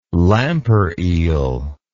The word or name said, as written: lampereel